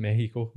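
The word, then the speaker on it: México: Yeah, in Spanish they don't pronounce the X, do they? – No, it's Mexico.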